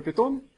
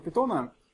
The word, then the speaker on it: Python: На самом деле читается как Python или Python в переводе на русский, но в русскоязычном сообществе уже принято говорить питон, поскольку так переводится из меня Python.